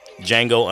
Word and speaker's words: Django: Django underscore nerd. The D is silent.